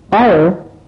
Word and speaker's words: hour: hour ساعت که اش تلفظ نمیشه.